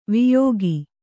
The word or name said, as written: ವಿಯೋಗ